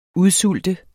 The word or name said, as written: UDSULTER